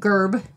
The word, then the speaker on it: Gurb: So again, that one is called No Word from Gerb. It's spelled G-U-R-B.